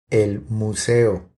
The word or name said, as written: El museo